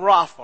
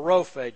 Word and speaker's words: Rapha: It's the word Yahweh, which is the word we get our word Jehovah from, and Rapha or Rophe, depending on how you pronounce Hebrew, okay?